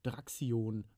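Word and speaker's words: Draxyon: Ähm, der, der, gute Herr hat einen Instagram-Account und einen Twitch-Account und äh, ich würde ihn jetzt äh, Draxion aussprechen. Ähm, verlinken wir sonst nochmal, aber geschrieben wird das D-R-A, großes X, Y-O-N.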